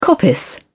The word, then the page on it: coppice: British English